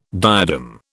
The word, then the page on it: diadem: British English